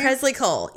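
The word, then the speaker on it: Kresley Cole: Because Cressley Cole, or how do you say her name? Do I say Cressley Cole. Yeah, she has a brand new book out today.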